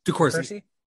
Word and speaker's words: DeCourcy: back to Michael, was it DeCalstri? Is that how you say his name? – DeCourcy. DeCourcy. DeCourcy.